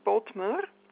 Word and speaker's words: Bodmer: Okay, it's B for, it's my name, Bodmer, B-O-D-M-E-R.